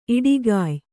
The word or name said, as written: ಈಡುಗಾಯಿ